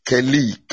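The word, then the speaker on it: keli (כלי): The Hebrew word is keli, K-E-L-I-Y. So it is, the phonetic is keli. You pronounce it keli, K-E-L-E-E, keli.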